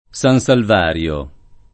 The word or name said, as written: San Salvario